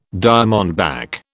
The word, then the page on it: diamondback: British English